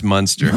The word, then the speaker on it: Munster: Monster cheese. – That was silly. – It's pronounced Munster. – Munster cheese. – Like Herman.